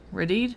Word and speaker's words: Rede: in the Frozen Triangle by Carlton Reed. – Rede. – For those who are wondering why we're having issues, it's spelled R-E-D-E.